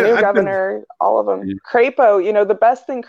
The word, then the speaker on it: Crapo: But armed, you know, and so Idaho and Mike Crapo in the Senate, perfect name from a guy from Idaho, because his ideas are going to be crap, but also rhyme with the name of the state, Crapo.